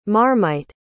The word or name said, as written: marmite